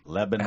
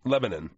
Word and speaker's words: Lebanon: Lebanon. – How long are you there? – We discussed this before. Lebanon. – Lebanon.